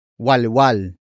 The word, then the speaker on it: walwal: Here is another Tagalog slang word, walwal. Walwal. Walwal. Walwal is spelled as W, A, L.